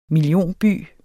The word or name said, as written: millionby